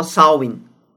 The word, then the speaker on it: Samhain: Samhain diye yazılıyor ama Samhain diye okunuyor. Bu Gaelic takviminin en önemli bayramı İrlanda'da, İskoçya'da ve The Isle of Man'de, Man adasında e, özellikle çok etkili.